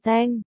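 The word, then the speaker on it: teng (疼): Chú thích, 1, 2, hai chữ đều được dịch là đau, nhưng trong tiếng Hoa cách đọc khác nhau. 1, đọc là tong, 2, đọc là teng, cách chương.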